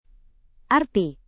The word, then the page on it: arti: indonesian